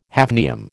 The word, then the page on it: hafnium: American English